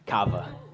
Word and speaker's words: kava: I learned how to say coffee, kava. Kava is how you say coffee in Croatian.